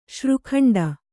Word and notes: ಶ್ರೀಖಂಡ: śrī khaṇḍa; Kannada

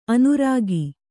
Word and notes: ಅನರ್ಘ: kannada

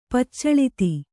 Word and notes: ಪಚ್ಚಳಿತಿ: Kannada

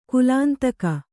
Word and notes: ಕಾಲಾಂತಕ: kālāntaka

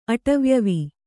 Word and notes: ಅಟವ್ಯವಿ: kannada

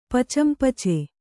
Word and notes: ಪಚಂಪಚೆ: Kannada

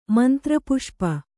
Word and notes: ಮಂತ್ರಪುಷ್ಪ: mantra puṣpa

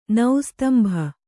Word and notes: ನಿಸ್ತಂಭ: nistambha

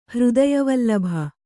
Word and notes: ಹೃದಯವಲ್ಲಭ: hřdaya vallabha

